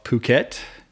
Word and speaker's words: Phuket: Uh, got to do some scuba diving off Phuket. You, you do have to pronounce that right. S P. It's P H U K E T.